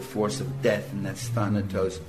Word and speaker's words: Thanatos: In German it would be Thanatos, but in English we say Thanatos, right?